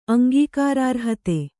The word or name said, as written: ಅಂಗೀಕಾರಾರ್ಹತೆ